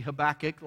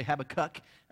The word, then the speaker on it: Habakkuk: Maybe you say his name wrong. The correct way would be Habakkuk. A lot of people say Habakkuk.